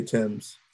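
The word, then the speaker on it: Tems: The Nigerian artist, is it pronounced Tim? – Yeah, Tim's.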